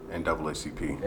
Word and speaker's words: NAACP: That'd be definitely something we talk to the NWCP. – NAACP. – NWACP, you know. – Still said it wrong. – R-E-S-P-E-C-T-O.